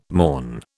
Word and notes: mourn: British English

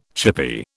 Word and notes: chippy: British English

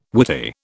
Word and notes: Whitey: British English

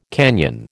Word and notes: canyon: American English

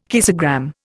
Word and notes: kissogram: Indian

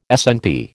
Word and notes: SNP: American English

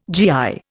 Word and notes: GI: Indian

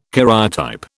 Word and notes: karyotype: British English

